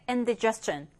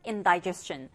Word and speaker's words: indigestion: 자, 그 다음 15번째 indigestion or indigestion. 자, 여기 di를 d라고 그냥 있는 그대로 발음하셔도 되겠고 die, 아, 이 사운드를 내셔서 발음하셔도 되겠습니다.